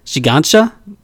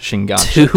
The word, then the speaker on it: Shiganshina: It's called To You in 2000 Years, The Fall of Shiganshima. Huh? – Shiganshina? Yes. – Okay. – And let's go over, it's... – Shiganshina. – I think it's pronounced Shiganshina.